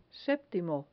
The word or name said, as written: septimo